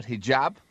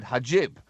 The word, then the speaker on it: hijab: The word hijab, meaning headscarf, of course. Instead, he said hajib.